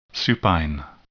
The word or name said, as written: supine